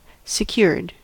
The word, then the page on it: secured: American English